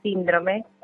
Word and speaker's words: síndrome: Y bueno, quería decirles también que la palabra síndrome no lleva acento en la O, como lo dice la locutora, lleva acento en la I.